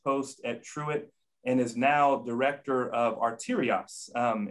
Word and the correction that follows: Artios: a, if I, I, get that right, Ron, did I say that right? Um, Artios. – Artios. – I knew when I said it, I didn't get it quite right.